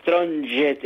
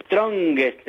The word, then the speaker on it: Strongest: No digan de Strongest, es Strongest el equipo de Bolivia.